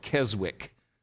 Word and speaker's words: Keswick: And it typifies something that is called Keswick theology, which is spelled K-E-S-W-I-C-K. Americans would often call it Keswick, but it's Keswick. It's one of those British words like Worcestershire sauce, you know, that it's pronounced differently than it looks.